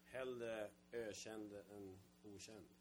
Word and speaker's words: Hellre ökänd än okänd: Hellre ökänd än okänd.